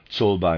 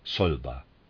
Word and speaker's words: Czolbe: Readers aside, Zolba, I'm pronouncing it that way, is spelled C-Z-O-L-B-E, Zolba.